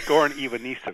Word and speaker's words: Goran Ivanišević: He will meet either Goran Ivanišević. Ron, help me with that pronunciation. Did I do it right? – Pretty close. Goran Ivanišević.